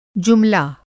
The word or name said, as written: जुमला